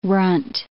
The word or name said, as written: runt